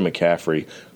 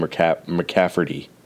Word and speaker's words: McCaffrey: He calls Christian McCaffrey Christian McCafferty. And he has for so long, and I've tried to correct him, and he doesn't catch it.